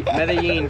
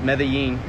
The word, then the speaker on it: Medellin: Yeah, you only butchered it like 3 times, but yes. Medellin for the listeners, Medellin. I've heard every combination of that name.